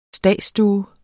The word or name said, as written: stadsstue